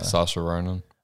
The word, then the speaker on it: Saoirse Ronan: Saoirse Ronan.